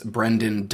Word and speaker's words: Brenden: Well, hello, I'm Brenden Lewis. Folks can find me on Linktree as Brenden Dice. That's spelled with all E's. There is two E's, no A's in my name.